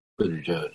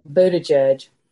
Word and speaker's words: Buttigieg: Buttigieg. – Oh, I love him. – Who? – Buttigieg. – Oh, Buttigieg, yeah.